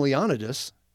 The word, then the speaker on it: Leonidas: King Leonidas, yes, that's how his name is actually pronounced,